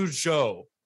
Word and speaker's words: Zhou: Juan Yu Zhou.